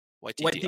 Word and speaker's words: Waititi: Taika Waititi? Did I say it right? It's Waititi. Okay. – Yeah. No, the first part you got right.